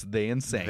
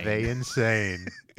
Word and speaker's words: Thein Sein: His name is pronounced The Insane. Spelt T-H-E-I-N-S-E-I-N. But you pronounce that, it's They Insane. – They Insane.